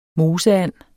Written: moseand

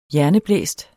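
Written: hjerneblæst

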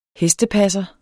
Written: hestepasser

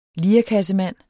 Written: lirekassemand

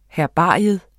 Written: herbarium

